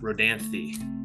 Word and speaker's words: Rodanthe: Well, I thought it was Rodan, R-O-D-A-N, but there's. – Oh, sorry, Rodantha, Rodanthe.